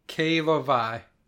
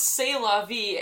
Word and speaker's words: c'est la vie: I love that I just glanced down to your notes and I see the direction mispronounced c'est la vie as qu'est la vie. – Yeah, purposely mispronounced. That's the joke.